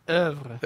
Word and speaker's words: oeuvre: Is it, how's it pronounced? Ouvre? – Oeuvre. – Oeuvre.